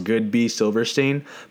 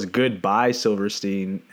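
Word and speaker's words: Goodby Silverstein: The company was, it's pronounced Good B Silverstein, but I had never heard it. I've only seen it on my screen. So I thought it was Goodbye Silverstein.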